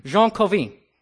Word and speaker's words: Jean Calvin: But DJ, you'll be pleased to know I've been practicing Jean Calvin. How's that? Good, bad? Close enough.